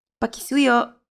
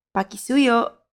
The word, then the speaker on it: pakisuyo: In Filipino, please is pakisuyo. Pakisuyo. Let's break it down by syllable. Pa-ki-su-yo. Now let's hear it once again. Pakisuyo.